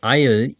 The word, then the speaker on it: isle: Das Wort ähnelt dem Wort Eil, I-S-L-E, was Insel bedeutet. Und es wird auch genauso ausgesprochen.